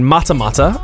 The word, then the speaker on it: Matamata: Are you in Metameta there, Sammy? That's where it says on our system. – Yeah, I am. – Sorry, correct pronunciation, Matamata. I'm learning that because my parents called it Matamata growing up.